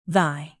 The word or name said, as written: thy